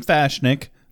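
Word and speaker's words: M'Fashnik: The Dawn shows him in the book page, and he confirms that it is pronounced Fashnik. Like cookies.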